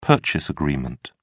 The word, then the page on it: purchase agreement: British English